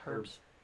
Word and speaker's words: herb: It's herbs. – Herbs. It's herbs. It's herbs. – Is it herb and spices. Or herb? Do you pronounce the H? – It's herb.